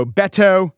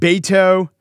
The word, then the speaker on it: Beto: Ditto Beto, who I should have called Beto.